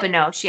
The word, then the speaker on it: Binoche: I don't know how to say her last name. Do you? Binoche? – It's Juliette Binoche, yeah.